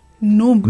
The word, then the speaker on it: núm: Ahí lo ideal sería que se dijera secundaria técnica y luego la abreviatura de la palabra número, que es NUM. – NUM, no, con el acento en la U.